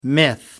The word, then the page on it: myth: American English